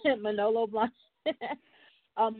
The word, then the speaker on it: Manolo Blahnik: And so your first job in fashion when you got to New York was with Milano Blahnik? Milano? Am I saying it right? – Manolo Blahnik.